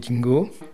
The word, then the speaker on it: dingo: Ils vont penser pendant très longtemps qu'elle est peut-être morte, bouffée par des dingos. Les dingos, c'est le chien australien. – Dingo ? – Oui, je prononce australienne, dingo.